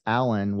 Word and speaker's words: Allan: My website is, I guess you can kind of see it on the Zoom here, but in case you can't, my name is spelled a little uniquely. So it's Allan with two L's and an A.